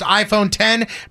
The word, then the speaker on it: iPhone X: The company said iPhone X is originally meant to be pronounced iPhone 10, but it is now known throughout the entire company and its users as iPhone X.